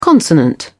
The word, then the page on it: consonant: British English